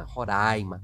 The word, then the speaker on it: Roraima: É, aliás, às vezes escapa né, a pronúncia, o nome certo de se pronunciar é Roraima, tá? Roraima, com o A bem aberto.